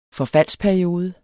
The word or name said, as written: forfaldsperiode